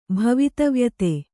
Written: ಭವಿತವ್ಯತೆ

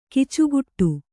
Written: ಕಚ್ಚೆಗಟ್ಟು

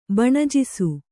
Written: ಬಣಜಿಸು

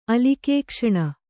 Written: ಅಲಿಕೇಕ್ಷಣ

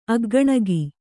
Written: ಅಗ್ಗಣಗಿ

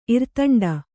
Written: ಇರ್ತಂಡ

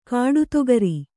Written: ಕಡಿದುಗಾರ

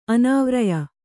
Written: ಅನಾವ್ರಯ

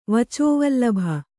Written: ವಚೋವಲ್ಲಭ

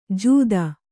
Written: ಜೂದ

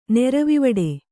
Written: ನೆರವಿವಡೆ

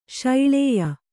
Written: ಶೈಳೇಯ

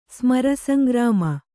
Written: ಸ್ಮರಸಂಗ್ರಾಮ

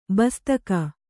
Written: ಬಸ್ತಕ

